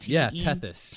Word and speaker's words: Tethys: Am I pronouncing that correctly? – Yep, Tethys. – T-H-Y-S.